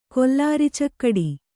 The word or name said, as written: ಕೊಲ್ಲಾರಿಚಕ್ಕಡಿ